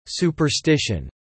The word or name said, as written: superstition